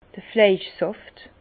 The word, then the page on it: d'r Fleischsàft: Bas Rhin; Herrlisheim